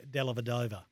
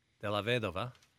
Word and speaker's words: Dellavedova: The man himself likes to get called Dellavedova. – Dellavedova.